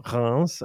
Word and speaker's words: Reims: It's primarily situated on the mountain around Reims or Reims, as you pronounce it in France, called the Montagne de Reims.